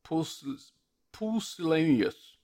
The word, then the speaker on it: pusillanimous: Pusillanimous. It's even hard to pronounce. It's P U S I L L A N I M O U S.